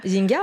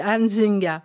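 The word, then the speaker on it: Nzinga: Voilà le vrai prononcé de ce nom, c'est Anne Zinga.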